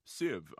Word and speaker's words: SIV: SIV stands for Strategic Investment Vehicle, and it's pronounced SIV, and we'll make the appropriate pun.